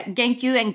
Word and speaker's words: Genkyuen: Is that the Genkyuen? – Yeah, Genkyuen Garden.